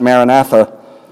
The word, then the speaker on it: Maranatha: There's a little Aramaic word, Maranatha. Some pronounce it Maranatha.